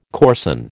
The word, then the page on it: coarsen: American English